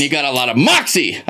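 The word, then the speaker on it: Moxee: It's not Moxie. It's Moxie. Think of it as two syllables.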